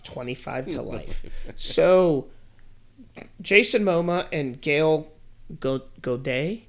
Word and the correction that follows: Gal Gadot: Gal Gadot. – Gal Gadot. I butchered the entire fucking thing.